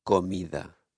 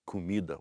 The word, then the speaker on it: comida: Which is spelled with an O. So in Spanish you hear? – Comida. – But now we're going to hear the Brazilian say comida with a U sound.